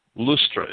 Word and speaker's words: Lystra: Lystra and Derbe, or Lustra. Lustra is probably the proper pronunciation.